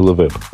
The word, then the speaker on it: LeWeb: И был я там на большой конференции под названием LeWeb. – И Левер. среди всех, ага. – да, ну, я постарался произнести так, как французы произносят.